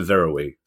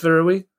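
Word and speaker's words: Varøy: It's spelled V-A-R, then O with a dash through it, Y, and I went for Verowy.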